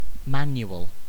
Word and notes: manual: British English

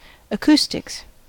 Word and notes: acoustics: American English

